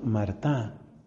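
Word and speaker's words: Martin: Ellos eran de apellido Martá. Se escribe Martín.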